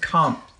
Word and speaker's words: Comte: Oh, Comte de Saint Germain. – So it's just Comte. – Okay. It's Comte. – Comte de Saint Germain, which I tried to keep that out of there because I knew you.